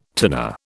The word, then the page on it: tinner: British English